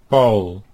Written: Paul

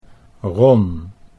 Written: Ron

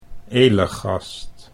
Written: Elegast

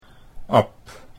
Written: Ab

